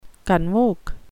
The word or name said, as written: convoke